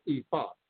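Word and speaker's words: Ifá: We say it in Yoruba, it's ifa. So often I say fa, ifa. – Okay. – Fa, ifa. It's how I usually pronounce it.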